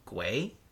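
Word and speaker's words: Guéhi: Mark Guay, Crystal Palace defender. It's G-U-E-H-I, and there's an accent in there. – Well, you... – I think I got it. – I think you did.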